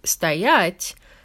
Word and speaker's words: стоять: And if you remember that, you'll automatically know that with стоять, the stress is on the second syllable.